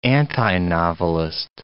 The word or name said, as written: antinovelist